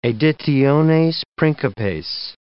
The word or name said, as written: editiones principes